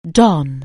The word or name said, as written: don